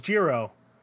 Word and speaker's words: Jiro: It is Jiro, but in a pronunciation I can't do because I'm a dumb American. – Got it. – But it is the hard J.